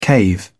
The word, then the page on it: cave in: British English